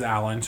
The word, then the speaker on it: Alan: My mom used to call me, because my name is Alan, so it's spelled A-L.